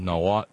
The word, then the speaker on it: Nahuatl: The original language in it is English, but there's a ton of Nahuatl words in it. – Nahuatl. – It's my understanding you do not pronounce the L. It's a silent L. I pronounced the L for years until I got corrected.